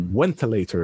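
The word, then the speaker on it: wentylator: He says, I pronounced it as if I was Polish. Like, I gave it the wrong starting sound. Yeah, because V sounds like W in Polish, at least. So it's like ventilator is how I should have pronounced it.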